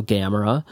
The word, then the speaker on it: Gamera: spelled like Gamma Ray, G-A-M-M-A, and then Ra, so Gamera.